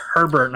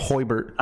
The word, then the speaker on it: Herbert: I said Herbert, not fuck off, dude. – Justin anyway, Hoibert. – I want to see how Justin Herbert does in the Hoibert. Postseason to see. I didn't say it like that, bitch.